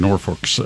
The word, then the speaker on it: Norfolk: Union Pacific in Norfolk Southern.